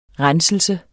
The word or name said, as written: RENSELSER